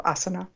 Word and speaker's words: asana: How did I do on the pronunciation of asana? – Pretty good. Yes, that one you got. It's got these short and long, the and the ah, which is the thing that people get most confused.